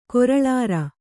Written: ಕರುಳಿರಿ

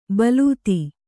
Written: ಬಲೂತಿ

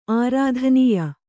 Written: ಆರಾಧನೀಯ

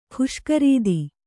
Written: ಖುಷ್ಕರೀದಿ

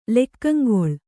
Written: ಲೆಕ್ಕಂಗೊಳ್

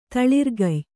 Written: ತಳಿರ್ಗಯ್

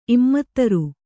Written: ಇಮ್ಮತ್ತರು

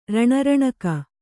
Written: ರಣರಣಿಕೆ

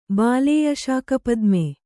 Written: ಬಾಲೇಯಶಾಕಪದ್ಮೆ

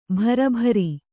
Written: ಭರಭಾರಿ

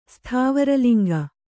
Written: ಸ್ಥಾವರಲಿಂಗ

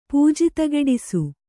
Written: ಪೂಜಿತಗೆಡಿಸು

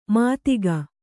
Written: ಮಾತಿಗ

